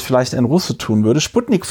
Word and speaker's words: Sputnik: Ähm, und sie hat mir erklärt, man würde das bei, bei Sputnik, man würde das mit wie so ein SZ aussprechen, das S. Also so ein sehr weiches S.